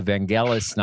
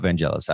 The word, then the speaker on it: Vangelis: Directed this movie, and the music is by Vangelis, not Vangelis. I always say it wrong.